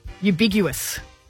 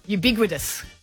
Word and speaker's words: ubiquitous: Ubiguous. – What? Say it properly. – Geez, you're very ubiguous, Kat. – Ubiguous or ambiguous? – Ubiquitous.